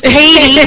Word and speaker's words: Cady: Oh, she said Elizabeth Cody Stanton. – Ooh. – So Rose is like, it's Cady.